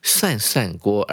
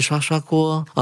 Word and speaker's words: 涮涮锅: For example, Japanese people 啊，日本人呼应到上一句的 Japan，日本人喜欢吃所谓的 shabu shabu，中文要念成涮涮锅，而不是刷刷锅哦。